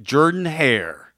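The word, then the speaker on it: Jordan-Hare: Finally, on this date in 2013, the prayer at Jordan Hare. Now, it's not Jordan. I know it's spelled Jordan, but we've been corrected. – It is Jordan Hare. – It's Jordan Hare.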